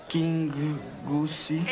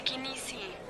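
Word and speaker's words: Kingussie: Hola, señora McIntosh de King Goosey. – Es King Goosey, amor. Se pronuncia Kinisi.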